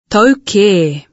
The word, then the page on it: tau kē!: Te reo Māori